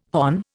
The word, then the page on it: hon: Indian